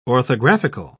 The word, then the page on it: orthographical: British English